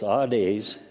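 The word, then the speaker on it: Hades (ᾅδης): It would be pronounced Hades because there is no H, but it has the rough breathing sound, so it becomes Hades.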